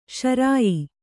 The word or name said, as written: ಶ್ರಾಯ